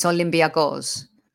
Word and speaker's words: Olympiacos: Now I need to, because having you on here has enlightened me to the different pronunciation the correct pronunciation of, is it Olympiacos? Is that better? – Yeah. – Okay. So like a Greek, it's Olympiacos.